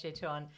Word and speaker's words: Etain: We've been alerted to this by Eitan. Thank you so much, Eitan. – Say that name again. – Eitan? – Eitan. – Eitan? – Yeah. E-T-A-I-N.